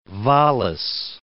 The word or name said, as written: Wallis